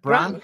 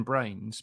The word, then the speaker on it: Brown: Actually, I used to be called Brian Brains because I can't pronounce my surname properly. And when I used to like check into hotels and stuff, I'd always say this name, James Brown. Brain.